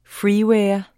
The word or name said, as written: freeware